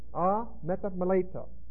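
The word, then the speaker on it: Ah, meta meleta (ἀμεταμέλητα): A-M-E-T-A-M-E-L-E-T-A. Long E. Ah, meta meleta.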